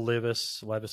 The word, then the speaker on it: Levis: Do you think some teams though would prefer him to throw considering Anthony Richardson, Will Levis, I believe I pronounced that name correctly.